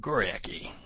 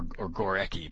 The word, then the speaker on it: Górecki: and the even better known Henryk Górecki, or Górecki, I beg your pardon to pronounce it correctly.